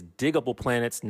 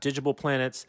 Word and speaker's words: Diggable Planets: So first and foremost, the hip hop group that you're looking for is Diggable Planets, not Digible Planets.